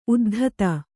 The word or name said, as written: ಉದ್ದಿತು